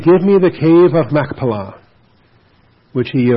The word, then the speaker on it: Machpelah: It's not Mach, it's Mech, with a cough. Machpelah. "That he may give me the cave of Machpelah, which he owns, which is at the end of his field."